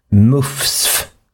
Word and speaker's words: MUCF: Och det här resulterat då i att Myndigheten för ungdoms- och civilsamhällesfrågor, MUFS, M-U-C-F, det skrivs ofta i tidningsartiklarna med små bokstäver, vilket antyder att man ska uttala det MUFS,